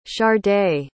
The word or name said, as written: shardae